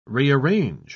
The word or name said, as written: rearrange